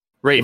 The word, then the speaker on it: Rafe: The ultimate goal for me is I forget to say how to say his name is Raph from Uncharted 4. – Rafe. – It might be Rafe. – Yeah. – R-A-F-E.